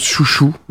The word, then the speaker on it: Xiu Xiu: If you want to send it over, let me hear you say, Deuxième titre donc de Xiu Xiu, ça s'écrit X-X-I-U-X-I-U, mais je crois que ça se prononce Chouchou, un truc comme ça.